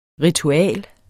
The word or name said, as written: ritual